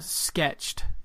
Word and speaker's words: SKTCHD: But, I feel like he does it more than once a month, and it's Sketched, which is spelled without any vowels. So SKTCHD, Sketched podcast with David Harper.